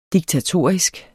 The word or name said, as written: diktatorisk